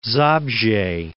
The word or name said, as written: Zabrze